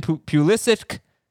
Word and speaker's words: Pulisic: faces Honduras as the Americans, led by Christian Pulisic.